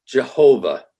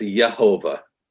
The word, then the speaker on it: Jehovah: But the Lord, and whenever you see Lord in all capital letters, that's Jehovah, or could be pronounced Yehovah or Yahweh.